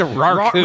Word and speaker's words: raccoon: Is the proper pronunciation raccoon or raccoon? – I always went raccoon. – I did raccoon too. – Emphasis on the rack. – The rack, yeah. The rack. It's a raccoon.